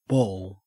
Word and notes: ball: Australian English

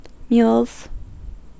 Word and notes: mules: American English

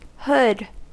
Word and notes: hood: American English

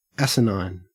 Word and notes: asinine: Australian English

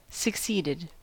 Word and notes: succeeded: American English

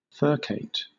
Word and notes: furcate: British English

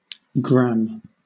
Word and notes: gramme: British English

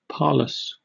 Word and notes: parlous: British English

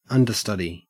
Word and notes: understudy: Australian English

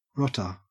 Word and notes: rotter: Australian English

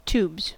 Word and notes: tubes: American English

